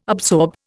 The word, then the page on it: absorb: Indian